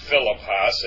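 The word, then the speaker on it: philippos (Φίλιππος): It's not philippos, it's philippos. Philippos. The accent on the first iota, and iota is pronounced iota, not iota.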